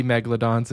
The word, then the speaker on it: megalodon: and there's a megalodon just waiting for you.